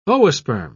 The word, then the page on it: oosperm: British English